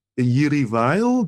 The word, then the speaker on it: Jiří Weil: Mendelssohn is on the Roof by Jiří Weil.